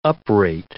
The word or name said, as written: uprate